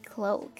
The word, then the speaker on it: cloak: 好，来看下一个词，叫做 cloak, C-L-O-A-K, cloak. 它可不是闹钟 clock 啊，这个词读作 cloak, cloak.